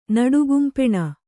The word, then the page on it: ನಡುಗುಂಪೆಣ: kannada